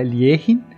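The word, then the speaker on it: Alekhine: O Alequine era russo, a gente, todo mundo conhece como Alekine no Brasil, tá? Então, assim, a gente falando aqui, todo mundo vai falar Alekine. Mas a pronúncia do nome dele correta é Lierin.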